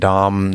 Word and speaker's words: Domhnall: I think this is how he pronounced his name. Dom. There's an H in there.